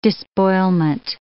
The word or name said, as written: despoilment